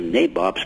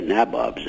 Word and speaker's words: nabobs: Hey, you got the pronunciation of nabobs correct. – Thank you. – Very few people do that. They all say nabobs, and they're wrong.